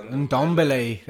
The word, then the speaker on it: Ndombele: Oh yeah, Ndombele. That's how they say it.